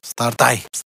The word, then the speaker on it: Start AI: Cum pronunț? Start AI sau Start AI, nu? Start AI. – Ai, n-ai, ai.